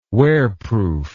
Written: wearproof

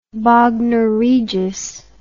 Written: bognor regis

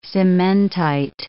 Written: cementite

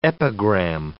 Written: epigram

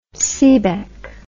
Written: seebeck

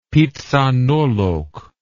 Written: phitsanulok

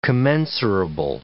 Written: commensurable

